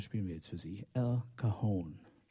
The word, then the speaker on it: El Cajon: Und in diesem Ort hat Johnny Mandel ein Stück geschrieben und das hat er genannt El Cajon und hat es aber immer ausgesprochen El Cajon, weil das war seine Hommage an Al Cohn, berühmter Saxophonist.